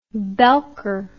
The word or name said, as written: belker